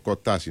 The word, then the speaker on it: Κοτάς: Πάση περιπτώσει, για να μην, για να απαντήσω και στο ερώτημά σου, το μόνο όνομα που εμφανίστηκε στο προσκήνιο είναι του Δημήτρη Κότα, πώς προφέρεται. Αυτός ο του ΠΑΟΚ, στόπερ, καταλόγου Β, και περιμένουμε να δούμε αν θα προχωρήσει αυτή η περίπτωση. Είναι το Κοτάς, είναι Δημήτρης Κοτάς.